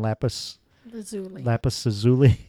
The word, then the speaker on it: lapis lazuli: It was I can't pronounce this name, the deep blue one, lapis lazuli, is that how you say yeah.